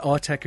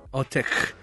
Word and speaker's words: Autechre: It has no fixed pronunciation and, like... That's something I'd get a lot when I was younger, and like you'd be finding electronic musicians for the first time and they'd have mad names like Autechre. Well, Autechre is how I've settled on pronouncing their name.